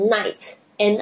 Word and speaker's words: night: 比如说像 night，n i g h t，它的 i g h 基本上都没有发音，所以你会看到很多的字，比如说像 light 灯光，灯光 l i g h t 那个 i g h。